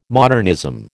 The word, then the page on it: modernism: American English